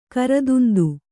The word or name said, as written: ಕೃತಾಂತ